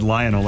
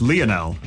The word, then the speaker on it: Lionel: I said Lionel, like Lionel Richie. – He's been corrected. – Lionel. However, Carl, who texted and said it's Lionel, but I believe it's Lionel. Emphasis on the first syllable.